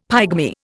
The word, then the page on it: pygmy: Indian